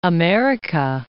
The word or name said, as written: America